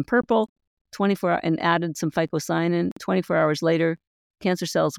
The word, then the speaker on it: phycocyanin: Fight that blue pigment in spirulina, which is called phycocyanin, P-H-Y-C-O-C-Y-A-N-I-N.